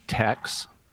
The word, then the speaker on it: THX: They would pronounce THX Tex.